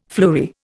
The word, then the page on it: fleury: Indian